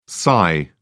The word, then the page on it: xi: British English